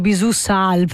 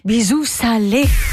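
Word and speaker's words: Bisou Salé: La prima volta che ho letto questo titolo mi ero detto Bisù Sal, perché nei titoli è scritto sempre in grande senza l'accento. Dico, ma come Bisù Sal? Bisù Salé.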